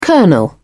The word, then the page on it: colonel: British English